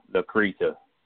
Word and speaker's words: Lacreta: The first word, L A C R E T A. Lacrete? Is that how you say it? – Lacrete. – Lac- Lacreta.